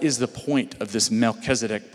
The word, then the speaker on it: Melchizedek: Say Melchizedek.